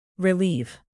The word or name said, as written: relieve